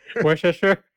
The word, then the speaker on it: Worcestershire: Worcestershire? – Yes. That's how you say the sauce, right? right? He is a music teacher. Who is Rowan? The music teacher from Worcestershire is entirely self taught.